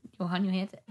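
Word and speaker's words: Jóhann Jóhannsson: Johan Johansson.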